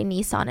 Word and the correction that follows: Nissen: it's not a Nissan, a Nissen funduplication procedure.